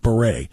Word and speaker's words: beret: There are like particularly French words like beret. You can say beret. There's no T at the end.